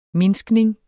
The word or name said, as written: mindskning